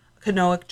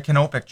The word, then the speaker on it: canopic: And we saw some real canopic jars. Is that how you say it? – Yeah, canopic jars.